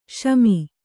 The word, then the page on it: ಶಮಿ: śami